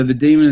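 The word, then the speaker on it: Dæmon: It's spelt weird. It's spelt D, so the demon is D-A-E, with that funny, you know how they do that funny alphabet thing where they'll join A and E? – Yes. – M-O-N, but you know, it's just pronounced The Demon.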